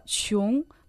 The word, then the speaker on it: 穷: Слово «чун» произносится также во втором доне.